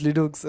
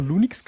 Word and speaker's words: Linux: Pronounce Linux as Linux.